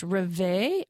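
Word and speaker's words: Rêve: The first two songs are going to be from this band called, and I think it's pronounced Reve, R-E-V-E, with a little accent on the second E.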